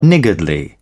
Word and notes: niggardly: British English

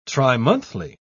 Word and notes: trimonthly: British English

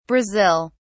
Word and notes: Brazil: British English

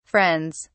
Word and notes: friends: British English

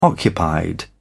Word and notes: occupied: British English

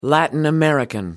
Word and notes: latin-american: American English

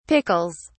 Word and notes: pickles: British English

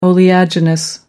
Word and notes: oleaginous: American English

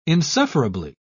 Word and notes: insufferably: British English

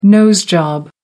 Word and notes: nose job: American English

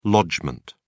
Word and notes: lodgement: British English

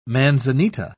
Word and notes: manzanita: British English

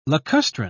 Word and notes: lacustrine: British English

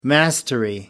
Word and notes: mastery: American English

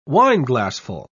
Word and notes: wineglassful: British English

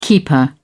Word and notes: keeper: British English

